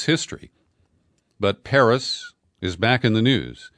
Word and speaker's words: Perris: I've never been to Perris, California. It's spelled P-E-R-R-I-S.